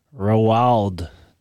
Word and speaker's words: Roald: Roald Dahl. – Is that how his name is pronounced? – Yeah, it's a weird name. It's like Ronald without the N. It's essentially the name Ronald.